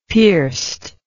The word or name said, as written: pierced